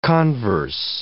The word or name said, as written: converse